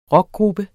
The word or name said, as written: ROCKGRUPPER